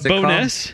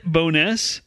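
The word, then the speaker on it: Bo'ness: They call it Bowness, but the name is actually Burrowstoonis.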